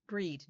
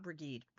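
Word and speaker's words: Bríd: Breed, not Brigide. Breed.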